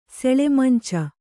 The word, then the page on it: ಸೆಳೆಮಂಚ: seḷe manca